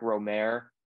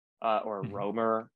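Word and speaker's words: Rohmer: Eric Romer, Romer, as most Americans say here.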